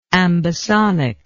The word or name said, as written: ambisonic